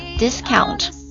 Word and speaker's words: discount: discount 是表示折扣嘛，打折嘛，优惠嘛， discount 它作为名词的时候读作 discount，比如说他们销售的所有商品都在打折，注意一下介词啊， they were selling everything at a discount， at a discount， at reduced prices，销售的所有商品都在打折。